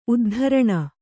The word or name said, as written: ಉದ್ಧರಣೆ